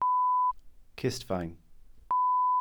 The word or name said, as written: cistfaen